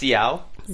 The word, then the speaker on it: Xiao: It's Xiao. – Xiao. – Xiao. Like almost like a T-S-Z.